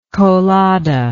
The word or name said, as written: colada